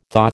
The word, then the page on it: thaw: American English